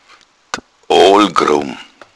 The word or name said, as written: odel